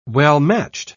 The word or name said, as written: well-matched